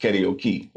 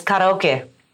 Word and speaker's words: karaoke: in Hawaii, and my buddy was like, no, it's pronounced karaoke. – Yeah, it's karaoke.